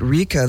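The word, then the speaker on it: Rikkha: And then a band called, I guess you pronounce it Rika, they're French, but the spelling is R-I-K-K. It's just like Rick spells his name.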